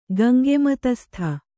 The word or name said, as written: ಗಂಗೆಮತಸ್ಥ